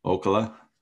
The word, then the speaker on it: Ocala: but the LPGA Drive On Championship at Golden Ochoa, or how do you say it? Ocala. Yeah. Golden Ocala.